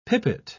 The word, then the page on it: pipit: British English